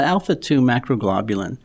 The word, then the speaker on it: alpha 2 macroglobulin: Yeah, alpha 2 macroglobulin.